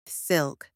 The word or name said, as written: silk